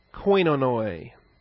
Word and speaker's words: koinonoi: Metakoi and koinonoi. K-O-I-N-O-N-O-I. Koinonoi. It has both the short O, the Omicron is the short O, and the Omega, this W-looking letter, is the Omega, is the long O.